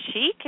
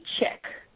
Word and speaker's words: Chic: Yeah, I mean, From my understanding, it's pronounced chic. – Cool. – But sometimes people intend it to be chick.